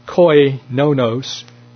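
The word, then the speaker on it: koinonos (κοινωνός): The Greek word for partaker is koinonos, K-O-I, should be pronounced koinonos, K-O-I-N-O-N-O-S, koinonos, meaning a partner and a sharer of anything.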